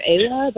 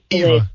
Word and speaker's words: Ewa: Yeah, it's EWA. Yeah. So I've been calling her Ava, but I think it's Eva. It's just Eva.